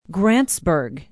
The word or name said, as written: grantsburg